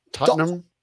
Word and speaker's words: Tottenham: Tottenham. – Tottenham. Norwich. You're putting too much emphasis on the HB.